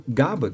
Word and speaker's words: Gabber: Gabba, que se escreve G-A-B-B-E-R, não é um dos subgêneros que eu gosto.